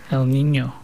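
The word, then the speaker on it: El Niño: Say that again, El Nino. – How do you say it? El Nino. – Nino. Is it coming from the Mexican side? – I think so.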